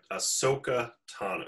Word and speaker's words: Ahsoka: Ahsoka Tana.